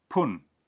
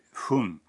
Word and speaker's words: 分: Por ejemplo, cinco minutos es y diez minutos es 10 Habrán notado que cuando 10, viene antes de fun, se pronuncia pun en vez de fun.